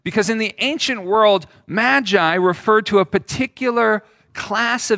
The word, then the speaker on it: magi: The Greek term there is magoi. We typically pronounce it magi.